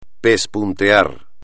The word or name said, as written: pespuntear